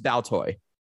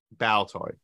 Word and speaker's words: Baltoy: It's pronounced Baltoy. – Baltoy.